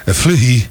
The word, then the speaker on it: Fleury: Mas eu gostava de uma coisa, o baiano no geral pronunciava meu sobrenome como realmente ele é Fleury.